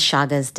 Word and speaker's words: Chagas: Yes, the actual name is Chagas disease.